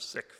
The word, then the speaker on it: Sikh: As you'll hear, Valerie is part of the Sikh faith tradition, the S-I-K-H. She pronounces the word Sikh, which is the traditional and, I believe, the correct pronunciation in Punjabi. But in Western countries, we often pronounce it Sikh, so that it's not confused with the English word Sikh.